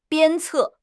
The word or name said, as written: 鞭策